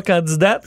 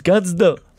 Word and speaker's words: candidat: Fait que là, ton attaché ou tes gens qui sont autour de toi français disent on prononce pas le T. tu, dis, pas candidat, tu dis candidat.